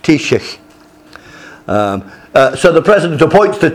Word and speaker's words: Taoiseach: Taoiseach. Taoiseach.